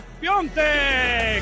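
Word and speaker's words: Piątek: Gol del Milan, se escribe Piatek, se pronuncia Cristo Piontek.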